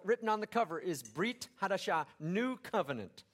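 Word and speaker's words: Brit Hadashah (ברית חדשה): Now, in Hebrew, the words New Testament are pronounced Brit Hadashah.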